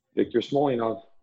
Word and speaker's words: Victor Smolyanov: Victor Smolyanov.